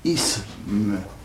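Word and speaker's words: -isme: Oui, il faut, la prononciation correcte consiste à prononcer un « se », un « s », « isme ». Voyez-vous ? Maintenant, la prononciation populaire consiste à mettre un « ze » à la place d'un « se ».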